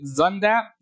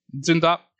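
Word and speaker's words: Zündapp: We're introduced to the bad guy, Professor, as they say it, Zundapp, which is not how you would say a name that is a German name that is spelled Z-U with an umlaut N-D-A-P-P. It'd be more like Zundapp. Zundapp. Not the point.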